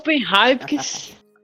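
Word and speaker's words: Heynckes: Jupp Heifes. Tá correto a pronúncia? Jupp Heifes. É. Heifes.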